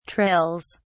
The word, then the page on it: trills: British English